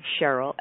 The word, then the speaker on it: Sherrill: It's Dr. Dr. Cheryl, S-H-E-R-R-I-L-L, Selman, S-E-L-L-M-A-N. So lots of R's, lots of L's in there. S-H-E-R-R-I-L-L-S-E-L-L-M-A-N, Dr. Cheryl Selman.